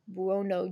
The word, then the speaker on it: Buono: It's B-U-O-N-O. Buono.